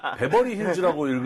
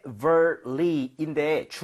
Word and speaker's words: Beverly: 우리 PD님이 아까 이걸 베버리 힐즈라고 읽으시더라고요. 실제로 우리가 베버리 힐즈라고 많이 썼어요. 옛날에 베버리지 아이들 뭐 이런 비디오도 있었고 드라마가 또 있었잖아요. 네네. 아무래도 그러다 보니까. 그런데 이제 우리가 조금 발음 규칙을 알다 보니까 좀 멋있게 발음을 했으면 좋겠습니다. So Beverly인데 중간에 V 들어갔죠? – Yeah. – 입술 붙나요? V는? – 안 붙죠.